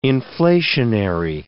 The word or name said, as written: inflationary